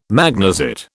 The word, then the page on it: magnesite: British English